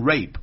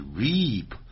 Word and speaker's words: reap: 那么这句话当然是有个固定用语， You will reap what you sow， You will reap， reap 就是收获，不要念成 rape， rape 是代表强奸 R-A-P-E 是 reap R-E-A-P， You will reap What you sow, sow 是 S-O-W, 你会收获了你所耕耘的东西， sow 就是耕种啊， S-O-W, 播种等等。